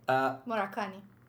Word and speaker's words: Moradkhani: Moradkhani.